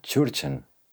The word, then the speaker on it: Tschurtschen: Die Tschurtschen. Die Tschurtschen. keine Ahnung. Super ausgesprochen. cono de abeto. – Cono de, ah, un cono de abeto. Ah. – Dann ein. – Tschurtschen.